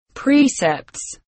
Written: precepts

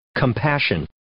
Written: compassion

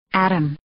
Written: atom

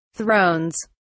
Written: thrones

